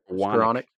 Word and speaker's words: Skowronek: The lowest percentage, and that's Higbee, uh, Skowronek, or however you say his name. – Any, Skowronek.